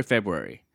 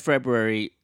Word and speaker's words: February: January, February. End of February, the rain level drops until the next winter. January, February. – That's a hard word to say. February. – It's like library.